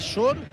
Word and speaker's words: Schor: Schor se pronuncia, ¿no? – Pronunciación difícil. – Ah. Es Schor.